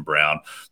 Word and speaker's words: Braun: I don't know why his name isn't pronounced Braun, but apparently it's Christian Brown.